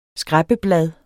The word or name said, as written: skræppeblad